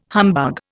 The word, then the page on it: humbug: Indian